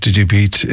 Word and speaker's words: DigiBete: Maddie Julian, DigiBete, that's pronounced DigiBete, if you want to get further details, from the App Store from there.